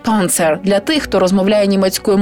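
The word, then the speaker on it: Panzer: Панцер. Танк. Вимовляйте це слово повільно, намагаючись вимовляти звуки зі справжнім німецьким акцентом. Із твердою «п» попереду, м'якою «р» наприкінці і вимовляючи «з» як впевнене «ц» всередині. Панцер.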